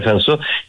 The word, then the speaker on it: Cinsault: Por ejemplo, hay cepas como el Cinsault, que se escribe Cinsault, o se pronuncia Sansó,